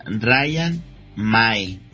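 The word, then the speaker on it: Ryan Mmaee: Y me dicen mis contactos, se pronuncia Ryan May.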